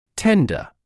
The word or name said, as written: tender